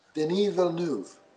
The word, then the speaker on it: Denis Villeneuve: Denis Villeneuve of Blade Runner 2049.